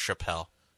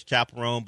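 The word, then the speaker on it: Chappell Roan: But I also thought it was pronounced Chappelle. – So. Yeah, we were trying to figure out how to pronounce it. I think I knew it was Chapel Rome, but we weren't exactly sure. – Feels like it should be Chappelle. – Yeah, it's spelled like Chappelle, except for the E. There's no E at the end of it, just like Dave Chappelle's name.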